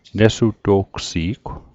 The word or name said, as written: nesuto'gsi'gw